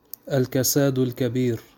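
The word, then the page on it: الكساد الكبير: Arabic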